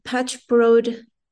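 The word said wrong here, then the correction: patch-robed: How do you say that? Patch broad. – Patch robed mendicants.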